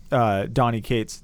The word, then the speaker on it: Donnie Cates: Which, nothing against Donnie Cates.